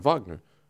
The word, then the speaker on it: Wagner: Yeah, it's German. He's German, Wagner. That's how he says his name.